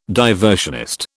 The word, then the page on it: diversionist: British English